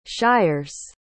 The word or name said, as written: shires